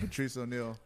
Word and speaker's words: Patrice O'Neal: So anyways, right, I only seen Eddie Murphy and Patrice O'Neal. – You said it right, Patrice O'Neal.